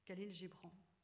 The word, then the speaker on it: Khalil Gibran: C'est, vous le connaissez tous, Khalil Gibran. Alors, je prononce à la française, je prononce pas comme à la libanaise les noms.